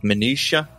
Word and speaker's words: Minuttia: George is the managing director of Minuttia. Have I pronounced that correctly as well? – You nailed it.